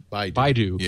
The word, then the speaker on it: Baidu: Is that Baidu. – Pronounced right? – Baidu.